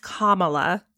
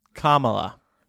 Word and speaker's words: Kamala: The pronunciation is Kamala. – Kamala. – Kamala Harris.